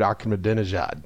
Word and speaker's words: Ahmadinejad: If you removed references to Christ and references to Muhammad, he is indistinguishable from Mahmoud Ahmadinejad. – I'm impressed you could actually pronounce that.